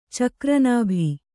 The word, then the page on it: ಚಕ್ರನಾಭಿ: Kannada